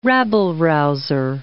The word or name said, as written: rabble-rouser